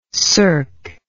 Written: circ

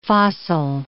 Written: fossil

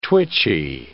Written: twitchy

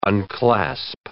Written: unclasp

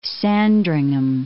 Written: Sandringham

